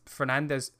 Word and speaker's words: Fernandes: You've got, let's say, Rashford on the left, Fernandes, as I've learned it's pronounced, Bruno Fernandes, in that number 10 role.